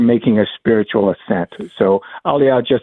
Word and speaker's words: Aliyah: So what is Aliyah? Is that how you, or Aliyah? Is that how you say it? – Aliyah. – Aliyah.